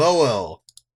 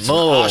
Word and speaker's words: Mohel: The Moel. M-O-H-E-L. The Moel. – I love this. – Is it the Moel? It's an Ashkenazi pronunciation.